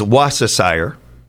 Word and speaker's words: Worcestershire: The most accepted pronunciation in American English is Worcestershire. The key is to drop the R and soften the C.